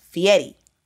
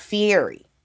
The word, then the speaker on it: Fieri: And so I was just saying Fieri, because that's how I usually hear it. But I had to go back and re-record because his last name is pronounced Fieri. It's not Fieri.